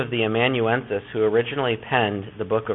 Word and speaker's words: amanuensis: The second question, many ancient letters were dictated to a secretary or amanuensis. That's how that's pronounced. Amanuensis, who physically wrote the letter.